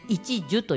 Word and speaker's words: 一樹: 木の難しい、普通に一つで木じゃなくて樹木の樹ですね、うん、はい、で一樹と読みますね。